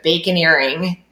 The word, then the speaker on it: baconeering: So more on the engineering, and I hope I'm going to say this right, because I feel like it's a word that you have coined, but baconeering. – Yeah, you said it right.